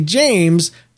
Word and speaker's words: James: And if I did, I would say James, kind of like sounds like James and people will be like, "Oh, okay, cool." Because there's only really two words that rhyme with James.